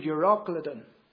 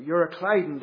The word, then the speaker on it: Euroclydon: But then that ship would meet with a great tempestuous wind named Euroclydon, or Euroclydon, depending on what way you want to pronounce it.